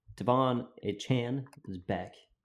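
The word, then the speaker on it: Achane: I mean, I like A-Chain a whole lot better, but he asked to be pronounced A-Chan, so I'm going to be respectful and call him A-Chan.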